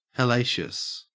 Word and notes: hellacious: Australian English

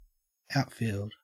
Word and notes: outfield: Australian English

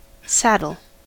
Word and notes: saddle: American English

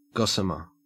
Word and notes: gossamer: Australian English

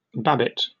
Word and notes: babbitt: British English

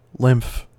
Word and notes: lymph: American English

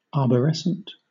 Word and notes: arborescent: British English

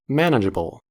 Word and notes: manageable: American English

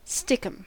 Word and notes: stickum: American English

